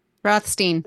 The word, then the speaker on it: Rothstein: Rothstein.